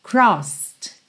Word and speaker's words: crossed: Então, sidewalks we crossed, crossed, sem pronunciar o E.